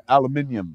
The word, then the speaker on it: aluminium: Aluminum. – As the British say, aluminium.